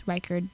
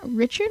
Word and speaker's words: Reichard: It's Reichard because it's R E I C H A R D, Reichard. reichard am I'm like, is that Richard? I'm like, no, it's Reichard.